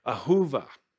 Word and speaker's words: Ahuva: But to think of it in the Hebrew language, it's pronounced Ahuva and means one who's greatly loved or one who's dear to the heart.